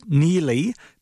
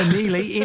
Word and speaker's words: Neagley: Okay, so that's Neely, but is spelt Neagly. Neagly. Yes. So Neely, N-E-A-G-L-E-Y.